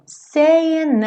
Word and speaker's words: saying: Logo nesse começo, esse saying, a gente já percebe esse G mudo, como quase sempre acontece, especialmente quando a gente tem ali um ing, né? Ing, seja gerúndio, seja o infinitivo, esse g é mudo.